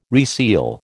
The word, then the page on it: reseal: American English